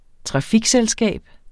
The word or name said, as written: trafikselskab